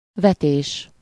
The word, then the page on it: cast: /ˈkæst/; /ˈkɑːst/; /kast/